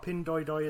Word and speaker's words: Pinduoduo: Pinduoduo, or Pinduoduo, as I've been told it's pronounced, is the biggest at 152.5 billion.